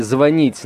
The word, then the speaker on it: звонит: говорят, что скоро м, произношение, точнее ударение в глаголе «звонить» на «о» станет нормой. Я даже произнести это не могу.